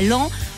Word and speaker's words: Laon: à Laon, mais ça s'écrit Laon, mais c'est à Laon,